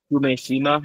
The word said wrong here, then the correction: Kumejima: Yeah, did you say Kumejima or Umejima? – Kume.